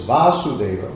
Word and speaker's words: Vasudeva: In that pure state, the Supreme Godhead, who is beyond the material senses and who is known as Vasudeva, with a long A, Vasudeva and Vasudeva, is perceived by my mind.